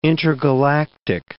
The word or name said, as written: intergalactic